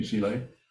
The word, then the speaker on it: Sihle: A question from, I'm not sure how to pronounce it, Sile? – How do you spell it? – S-I-H-L-E. – Sounds right to me, Sile.